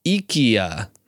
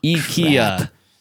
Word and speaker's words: IKEA: That, according to the phonetic pronunciation, might be pronounced IKEA. – No, it's not. – That's what I'm looking at. The first syllable is E-E. IKEA.